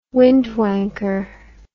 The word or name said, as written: windwanker